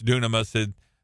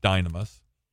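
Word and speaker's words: dynamis (δύναμις): And he said that word comes from the word dynamis or dunamis, is the way that if you pronounce it the way it looks, it's dunamis. It's probably better pronounced dynamis. The U and the Y in Greek are kind of backwards from the way we pronounce things in English.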